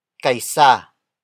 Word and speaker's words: kay: Kaisa. Kaisa. Kaisa is spelled as K-A-Y-S-A.